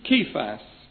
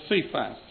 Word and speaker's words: Cephas: He was seen of Kephas, or Cephas, as some like to pronounce it.